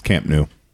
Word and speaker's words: Camp Nou: It's Camp Nou.